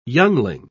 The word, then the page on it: youngling: British English